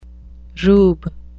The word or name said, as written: rube